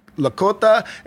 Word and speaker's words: Lakota: However, the character of Walking Sam himself has uh, existed among the Lak- Lakota and Dakota Native American tribes for some time now, with a record of him.